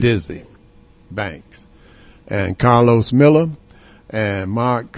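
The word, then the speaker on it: Desi Banks: They can go see Dizzy Banks, or Diaz Banks, however his name is pronounced.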